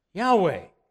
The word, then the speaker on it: Yahweh: Now, a couple of weeks ago when I read Scripture, when we came to the word Lord with all four letters capitalized, I didn't pronounce Lord. Anybody remember what I pronounced? Yahweh. That's God's name.